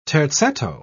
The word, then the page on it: terzetto: British English